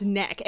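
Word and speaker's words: Nøkk: everyone was saying knock because that's what it looks like. But it turns out it's actually pronounced neck. And if you Google N-O with a slash through it K-K, the Wikipedia article is called neck, like the body part, N-E-C-K.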